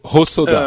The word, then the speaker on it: Hosoda: Hosoda, euh, Osoda. Osoda. Hosoda. Oui, c'est de l'eau. Le, H est... mélangé de l'eau et le H est sonore, donc c'est Hosoda.